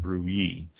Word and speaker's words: Brouilly: B-R-O-U-I-L-L-Y, pronounced Bruy. The reds of this region are really fruity.